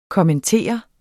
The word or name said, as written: kommentere